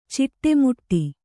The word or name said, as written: ಚಿಟ್ಟೆಮುಟ್ಟಿ